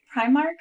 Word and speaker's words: Primark: Primark? – Primark. – Primark. – Primark. – P-R-I-M-A-R-K.